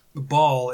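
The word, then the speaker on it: Baal: Ball is, but that's B-A-A-L.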